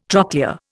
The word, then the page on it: trochlea: Indian